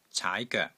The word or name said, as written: caai2 goek3